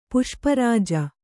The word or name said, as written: ಪುಷ್ಪರಾಜ